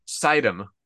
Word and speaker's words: Sidon: Sodom. Am I saying that right? – Sidom. – Sidom. Sidom. – It's like the like sexy shark from fucking Zelda, but with an M instead of an N.